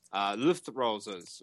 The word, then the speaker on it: Luftrausers: And finally, guys, we have, when this flipping page loads again, Luftrausers review. I think I pronounced that right. It's like Luftwaffe, except Luftrausers instead. This is by Anthony Chambers.